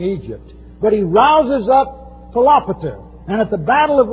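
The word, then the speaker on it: Philopator: 11th verse describes what historians call the Battle of Raphia, R-A-P-H-I-A. The successor to Euergetes is Philopater. These are names that kill you. Phonetically, Philopater. But it's Philopater.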